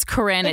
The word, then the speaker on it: Corinne: Guys, my name is Corinne. – Is it Karen or C-O-R-I-N-N-E. Please, it drives me fucking nuts.